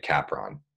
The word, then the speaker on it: Capron: So my family pronounces our last name Capron.